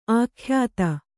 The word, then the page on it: ಅಖ್ಯಾತಿ: akhyāti